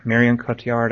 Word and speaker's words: Cotillard: but the real standout in Nine was Marion Cotillard. I don't know how to say her name. I'm a jerk. – I think you said it. That's correct.